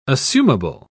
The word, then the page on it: assumable: British English